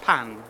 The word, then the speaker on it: pain: Did you know Fred's pain? – Beautiful Morrison. – Yeah. – Did I know what's pain? Sorry, in French, bread is pain. – Pan. – Pain. – It's pronounced different.